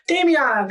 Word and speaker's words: Damiana: I think it's pronounced Dominia. Damania? I don't know. It's another... – Damiana? – Damiana. Thanks, Queen.